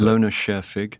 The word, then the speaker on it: Lone Scherfig: Lone Scherfig.